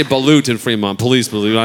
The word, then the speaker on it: balut: I think it's called balut. Is it balut, called? Yeah. Balut. I think is how you say it.